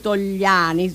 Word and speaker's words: Togliani: e Achille Togliani, se lo pronuncio giusto, sì.